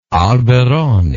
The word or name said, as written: alberoni